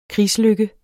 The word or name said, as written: krigslykke